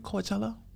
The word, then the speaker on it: Coachella: You know Coachella's been going on. – Yeah. – Am I saying it right? Coachella? – Coachella.